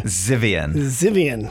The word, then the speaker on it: Zivian: And Eric, as another Z to another Z, how do you pronounce your last name? – Zivian. – Thank you. Appreciate that, because I have a notorious for not pronouncing people's names correctly.